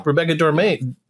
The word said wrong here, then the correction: De Mornay: I can't say her name for the life. – Rebecca De Mornay. – De Mornay.